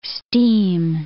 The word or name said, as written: steam